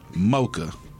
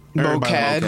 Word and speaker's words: MOCAD: See, I like to call it Mocha. – Mocad. Call it Mocad. Mocad. That's what I've been calling it, but I figured I had to pronounce it so we look decent.